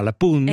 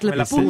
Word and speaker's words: La Punt: Et moi, j'ai appris comme parole, je ne savais pas qu'on dit, on dit pas le pont, on dit la, donc c'est féminin, pun, alors qu'il y a un T à la fin, mais on ne le prononce pas, la pun.